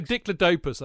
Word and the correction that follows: Diplodocus: Isn't it a Diplodocus?